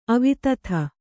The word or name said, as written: ಅವಧೂತ